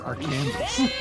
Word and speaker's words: Arkansas: You're not even pronouncing it correctly. It's Arkansas. We already dealt with this with Kansas.